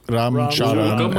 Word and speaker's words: Ram Charan: Rama Rao as Komaram Bhim, and then Ram Charan as Sitarama Raju.